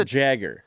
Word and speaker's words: Jagger: Every time I try and say his name, I'm like Yager Joshua. – Yeah. – It's Yager Joshua. It's a Jagger. – Try and say it. Yager Joshua. – It's Jagger. – Jagger Joshua.